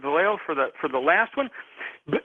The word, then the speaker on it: Vallejo: What's out of line is I was just reading Vallejo. Am I pronouncing the name right? Vallejo, California.